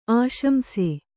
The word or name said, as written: ಆಶಂಸು